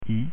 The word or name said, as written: i